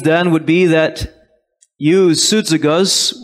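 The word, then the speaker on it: Soudzagos (σύζυγος): Well, the word yoke fellow that is transliterated from the very Greek is pronounced this way, Soudzagos.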